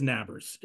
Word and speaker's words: Nabers: Neighbors, who I swear I know it's pronounced Neighbors, but that's one of those things in the broadcast. You look at that, I'm like, that says Nabbers. It's spelled Nabers. That is Nabbers. That is not Neighbors.